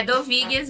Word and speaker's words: Dovigues: Eu estou pronunciando certo? do Doveguis. Como é que é o teu sobrenome, Marília? – É do Vigas mesmo.